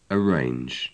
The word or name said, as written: arrange